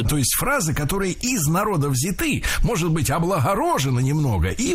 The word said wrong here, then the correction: взяты: Не взяты, а взяты. Ударение в этом слове сохраняется на первом слоге. Он взят, оно взято, они взяты. И только лишь в женском роде ударение переносим на окончание.